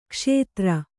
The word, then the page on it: ಕ್ಷುದ್ರ: kannada